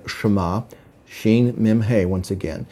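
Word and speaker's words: Shema (שמה): Now, the root word of Shemayim is a word pronounced Shema. It's spelled Shin Mem He.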